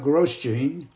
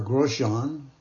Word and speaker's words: Grosjean: Here's a fundraising request from a friend, Leah Grosjean, or Grosjean, using the French pronunciation, is a Governor Moorhead School alumni.